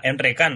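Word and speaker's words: Emre Can: Vamos a escuchar el resumen de María Candelaria, a ver qué nos cuenta sobre esta selección alemana, que cuenta como novedad a Enre Kahn, bueno, Enrique Chan se llama realmente, esa es la pronunciación en alemán.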